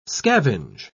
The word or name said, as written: scavenge